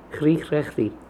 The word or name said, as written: Krikhrakhri